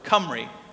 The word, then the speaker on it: Cymru: In Wales, the way that they say the name of their country, Wales, which we say in English, is Cymru. Okay? That's how you say it in Welsh.